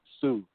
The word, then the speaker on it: TSU: Sue. It's spelled T as in Tom, S as in Sam, U as in uniform. Sue.